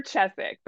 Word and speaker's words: Chesak: Yeah, it's Jennifer Chesak, but you can call me Jen throughout the podcast.